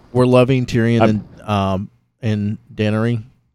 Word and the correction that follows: Daenerys: Yeah. – Daenerys. – Yeah, I knew I wasn't saying that right.